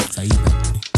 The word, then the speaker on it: Saipan: Saipan. – Saipan. – Which he said I'm saying that wrong. It's just Saipan. But it's not Saipan.